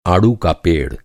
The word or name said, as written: आडू